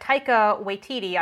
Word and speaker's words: Taika Waititi: And Taika Waititi, I hope I'm pronouncing that right, is also IG-11.